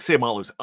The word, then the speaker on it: Seumalo: But Isaac Seumalo. That's how the Steelers want us to pronounce it. Isaac Seumalo's on that list as a winner.